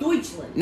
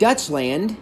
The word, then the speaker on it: Deutschland: It's called Deutschland. – Not Dutchland. Dutch, D, is different than Deutsch. – It's the same thing. It's how you spell it. D-U-T-H.